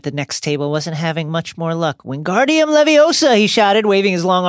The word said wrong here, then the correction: Wingardium Leviosa: You're saying it wrong, Harry heard Hermione snap. Of course. It's Wingardium Leviosa. Make the gah nice and long.